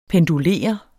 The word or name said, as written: pendulere